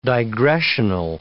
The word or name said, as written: digressional